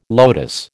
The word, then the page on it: lotus: American English